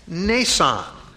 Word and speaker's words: Mnason: They were disciples of Caesarea and brought with them one by the name of Mnason. The M is silent.